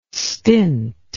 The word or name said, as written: stint